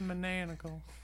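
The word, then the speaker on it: Manananggal: I heard you boys talking about gravy, and I wanted to come and tell you it's pronounced Manangal.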